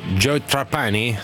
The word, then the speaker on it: Joe Trapani: Joe Trapani? O Joe Trapani, se volete pronunciarlo all'americana,